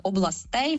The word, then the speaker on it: областей: Тут правило досить конкретне і ніяких розбіжностей немає абсолютно ніде. Тільки родовий відмінок областей, все інші слова ми ставимо наголос на першу «о».